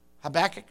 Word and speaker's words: Habakkuk: If I'm pronouncing that word right. Habakkuk, is that right? How do you say it? Habakkuk, okay. That sounds pretty good to me.